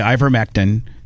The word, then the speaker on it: ivermectin: And they said ivermectin, whatever. I think I said it correctly. Ivermectin. Is that correct? – Ivermectin.